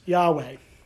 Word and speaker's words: Yahweh: And so we, being that we can't pronounce words without vowels, we insert vowels. And the way that we've chosen to say that word is Yahweh. Right, we put an A between the Y and the H, and then we put an E between the W and the second H, and we call it Yahweh.